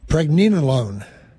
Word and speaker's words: pregnenolone: Pregnenolone. – Pregnenolone. – N-E-N-O-L-O-N-E.